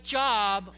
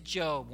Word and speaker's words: Job: Looks like Job, only we pronounce it Job. One of the great ironies, again, of the English language. It's not Job, it's Job.